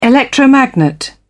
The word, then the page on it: electromagnet: British English